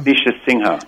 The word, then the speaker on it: Manisha Sinha: Manisha Sinha.